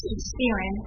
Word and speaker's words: Spearin: Spearin. – S-P-E-A-R-I-N.